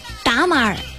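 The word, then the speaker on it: tamal: 他们的粽子呢叫做达马尔，就是他们的发音叫达马尔。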